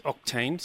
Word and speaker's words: Octeine: So is that how you pronounce Octeine? So it's spelt O-C-T-E-I-N-E. So is that how you spell it? You pronounce it? – Perfect, mate. Yep, you've nailed it. It's actually it's two words combined. So it's the O-C-T from octane, as in the octane number of petrol, mm-hmm.